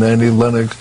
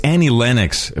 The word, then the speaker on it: Annie Lennox: and then it led to Whitney and Annie Lennox. When I saw that audition... – By the way, it's Annie Lennox. For someone you claim to have discovered, at least get her name right, Clive.